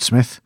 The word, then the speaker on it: Psmith: It's about a guy whose name is Rupert Smith, but it's spelled P-S-M-I-T-H. – Smith? – Smith. – Oh, yeah? – There's a silent P.